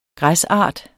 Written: græsart

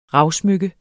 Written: ravsmykke

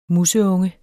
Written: museunge

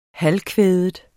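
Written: halvkvædet

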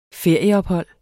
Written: ferieophold

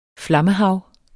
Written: FLAMMEHAVET

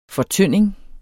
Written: fortynding

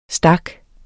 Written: stak

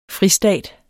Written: fristat

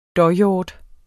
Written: dåhjort

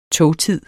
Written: togtid

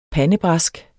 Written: PANDEBRASKERNE

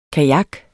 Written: kajak

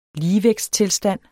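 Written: LIGEVÆGTSTILSTAND

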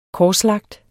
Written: korslagt

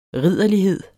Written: ridderlighed

